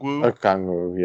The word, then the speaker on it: Okongwu: Okongwu, yeah.